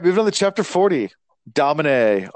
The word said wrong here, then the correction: damane: Damane. Damane. I think the way they pronounce the audiobooks is Damane. I might be wrong, but yeah.